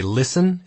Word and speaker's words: listen: It is terribly difficult to get a student to say listen instead of listen when he sees a word like L-I-S-T-E-N. This problem vanishes as soon as I teach them a phonetic alphabet. No one pronounces the T in listen when they're reading it phonetically.